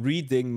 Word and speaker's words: Reading: Am nächsten Tag sind wir, das war ganz geil, direkt quasi ein Block neben uns war so ein Reading, der heißt Reading wird es ausgesprochen, aber wie Read, also. Wie lesen aus, also geschrieben Reading Market,